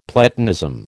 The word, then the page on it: Platonism: American English